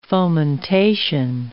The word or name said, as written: fomentation